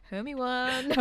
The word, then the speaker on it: Hermione: Hermione. Legit, like phonetically sounds like Obi-Wan Kenobi. – Yeah. – She was very wise. – Hermione.